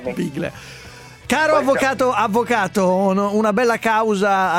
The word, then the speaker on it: Biglia: che si pronuncia così, Biglia.